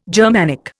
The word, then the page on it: Germanic: Indian